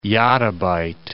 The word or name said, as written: yottabyte